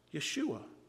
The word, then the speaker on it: Yeshua: The Hebrew word for Jesus is Yeshua. Yeshua. Yeshua, that's how it would have been pronounced in Hebrew, Yeshua.